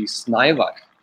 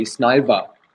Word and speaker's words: Snævar: I've heard it pronounced in a thousand different ways, but the correct way and the way my mother would pronounce it would be Snævar. – Snævar. – Yes. Usually the Danes say Snævar, so you don't roll on the R, but you can go with both.